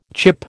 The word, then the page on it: chip: American English